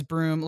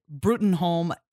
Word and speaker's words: Bruttenholm: Also, can I say that I have to manually pronounce Trevor's last name as Broom. Like, I read it Bruttenholm every single time, and then I have to be like, it's Broom, it's Broom, it's Broom.